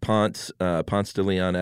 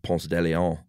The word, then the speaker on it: Ponce de Leon: And, you know, at Ponce de Leon Avenue here in Atlanta, uh-huh. everyone that doesn't live in Atlanta probably laughs that we pronounce it that way instead of Ponce de Leon.